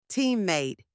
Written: teammate

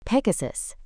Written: pegasus

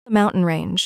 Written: mountain range

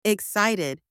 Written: excited

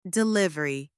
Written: delivery

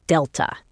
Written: delta

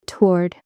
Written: toward

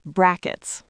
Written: brackets

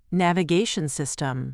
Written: navigation system

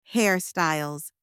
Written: hairstyles

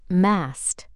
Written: mast

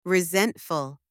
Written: resentful

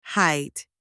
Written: height

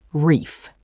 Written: reef

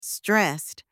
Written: stressed

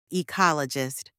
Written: ecologist

